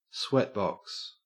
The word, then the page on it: sweatbox: Australian English